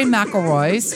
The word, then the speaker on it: McIlroy's: Now, Kitty immediately went into the coop, pulled out her phone, and showed me a picture of her on Rory McIlroy's, did I say that right? – Yes, you did.